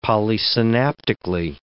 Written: polysynaptically

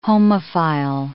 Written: homophile

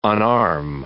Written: unarm

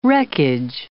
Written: wreckage

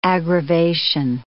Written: aggravation

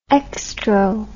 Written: extro

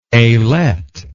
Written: ailette

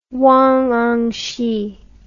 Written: Wang Anshi

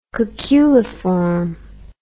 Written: cuculiform

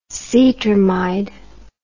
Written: cetrimide

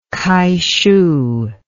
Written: kaishu

